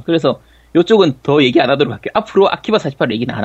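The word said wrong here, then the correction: AKB48: 예, 우리가 비할 의도는 없었고, 그냥 아키, 아키바라고 읽기가 편해서 그렇게 읽은 건데, 네. 일단은 뭐, AKB 48.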